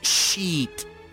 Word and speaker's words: sheet: Porque la palabra, first of all, tenemos que hablar un poco de la pronunciación. Porque hay la palabra mierda en inglés es shit, shit. Y también hay la palabra que es para sábana, que es sheet Sheet. So, tenemos que shit. Este vocal, esta vocal es muy difícil de pronunciar para los hispanohablantes.